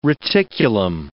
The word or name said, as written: reticulum